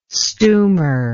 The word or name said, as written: stumer